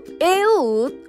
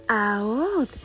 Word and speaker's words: Aod: Ese Salvador fue un zurdo llamado Eud, o bien pronunciado Aod, hijo de Gerá de la tribu de Benjamín.